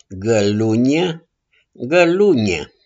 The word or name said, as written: GĂLUNIE